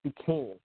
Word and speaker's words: Duquesne: That's how it's pronounced, Duquesne.